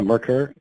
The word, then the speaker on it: Merkur: Now, the one car you mentioned that you don't currently own that would fit into the next generation, is that Mercure? Is that how you say it? – Yeah, Mercure. Yeah, absolutely.